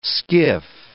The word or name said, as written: skiff